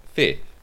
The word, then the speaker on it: Phi: Phi.